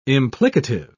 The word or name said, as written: implicative